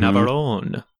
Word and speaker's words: Navarone: So the island they're on is called Navarone Island, if assuming I'm pronouncing that right. – Mm-hmm. Navarone.